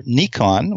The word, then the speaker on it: Nikon: I have done a lot of work for Nikon, which is the correct pronunciation, which is, when I get pretentious, that's how I say it.